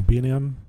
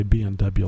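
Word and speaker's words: B&M: I got a, a uh, BN uh, I always say this wrong. B and M. Yep. – B and M. I always want to say B and W like uh, A and W root beer.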